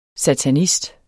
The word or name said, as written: SATANIST